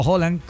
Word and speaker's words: Haaland: Siento, Poncho, que Noruega, por el tema Haaland o Holland, como es correctamente como se debe pronunciar, pero el tema de la coreografía esta vikinga y algunos detalles han empezado a enamorar a muchos hinchas.